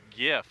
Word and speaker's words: GIF: I would call it a GIF. – All right. Sold. – I'm going to say that's a hard G there.